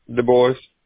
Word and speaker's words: Dubois: Du Bois. – How do you spell that? – D-U-B-O-I-F. – D-U-B? – O-I-F. – O-I-S.